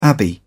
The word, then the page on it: abbey: British English